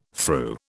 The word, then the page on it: thru: British English